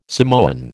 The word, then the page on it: Samoan: American English